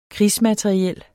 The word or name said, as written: KRIGSMATERIEL